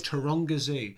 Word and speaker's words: Taronga Zoo: Taronga Zoo.